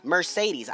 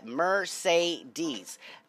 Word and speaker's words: Mercedes: Mercedes has Mercedes. Understand it has three E's in there. All pronounced different. Mer, M-E-R, say, C-E-D, E's, E-S. Bitch, if I see an E, it's pronounced like an E.